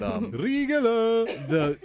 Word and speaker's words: Riegele: Their Oktoberfest this year, they actually partnered with a 600-year-old German brewery called Brauhaus Riegele. I'm sure I'm butchering that, but Riegele.